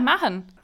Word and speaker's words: Mahon: You know, I don't think I've ever heard someone pronounce the H in the middle of Kevin's surname. – Really? That's how you're supposed to say it? – Really? – Yeah, Mahon. – I always just figured you'd do the Vince McMahon thing and just sort of bounce that H.